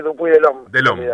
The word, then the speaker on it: Dupuy de Lome: Eh, la E no se pronuncia, dice Dupuy De Lome, bueno, está bien.